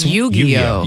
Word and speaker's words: Yu-Gi-Oh: I don't know how to pronounce this. 2692 said Yu-Gi-Oh? – It's Yu-Gi-Oh.